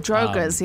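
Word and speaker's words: draugr: and it's this like I always pronounce it wrong, Dwoggers, but it's it's uh, the, the the zombies from um, Skyrim, one of those guys. – Oh, Drogas, um, yeah.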